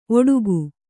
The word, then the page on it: ಓಡಿಕೆ: Kannada